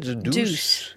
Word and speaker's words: The Deuce: The Deuce.